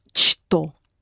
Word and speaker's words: что: However, you have the letter ч, which in this particular word is pronounced ш. Because it's difficult to say this combination of sounds, что. So you say, что.